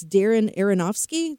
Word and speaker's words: Aronofsky: it's Darren Aronofsky.